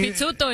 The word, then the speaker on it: Pizzuto: Él dijo que suena como pizza. Él dijo Pizzuto. Así. Mi nombre es. Mi, Pizzuto, entonces. – Se pronuncia como pizza.